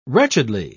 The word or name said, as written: wretchedly